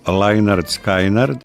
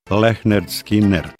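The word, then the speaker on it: Lynyrd Skynyrd: izašao je debitantski album američke Southern rock grupe, mi to izgovaramo Leonard Skynyrd, inače bi trebalo Lehnard Skynyrd,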